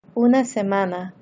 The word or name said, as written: una semana